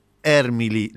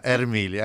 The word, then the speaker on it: Ermili: Y para ello nos comunicamos con una compañera de la Asamblea Permanente por los Derechos Humanos, la APDH, que es Claudia Ermili. ¿Lo dije bien el apellido, Claudia? Buen día. – Hola, buen día. Sí, en realidad es Ermili, ahí bien está. Italiano. – Ahí está, Ermili, ahí está.